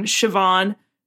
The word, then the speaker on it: Siobhan: Siobhan. Like Shiv, right? – Yeah, like Shiv Roy, yeah.